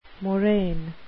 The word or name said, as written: moraine